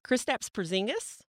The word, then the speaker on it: Kristaps Porzingis: If Kristaps Porzingis, did I say that right? – Yes, that was good.